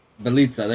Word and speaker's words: Jelica: Like, you know, Nemanja Jelica, or however you say his name. I know you probably know it. Belitza, there you go.